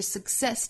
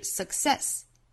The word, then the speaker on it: success: 这句话有两个要点，一个是 success 这个词。它包含两个音节，所以读的时候不要读成哒哒哒 ,success, 应该是 success, 哒哒哒哒。在中间的这个 k 的发音位置后面不要拖出元音来，不是 success, 应该读 success, success,